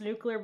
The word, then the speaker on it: nuclear: I was just thinking, you can tell that Cam is very far away from the conservative he used to be because he actually pronounced nuclear with two syllables. And I feel like anybody who's Republican is too busy saying nuclear every dang time, drives me insane.